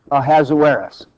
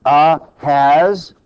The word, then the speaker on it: Ahasuerus: Can you say Ahasuerus? It's one of the words you... When you look at it, it's kind of hard to pronounce. So Ahasuerus, now you're a Bible scholar. So that's just the Hebrew way of saying his name.